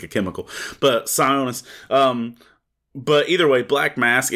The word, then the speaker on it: Sionis: It gives us a nice little childhood backstory on Roman Sionisus. Is that how you say his name right? Yeah, Sionisus.